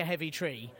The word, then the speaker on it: Heavitree: Right, yeah, but it's not like a heavy tree. It's it's like Havertree. – It's spelled Heavitree, but it's pronounced Heavytree. It's in ear, not an E, yeah.